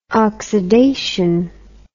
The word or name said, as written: oxydation